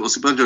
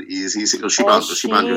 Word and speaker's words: Osinbajo: No, Osinbajo is an Osinbajo is an. It seems like Osinbajo. – It's a sh, not a s.